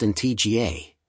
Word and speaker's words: Antigua: Antigua, pronounced in TGA, and Barbuda, Barbuda, is located in the heart of the Caribbean Sea.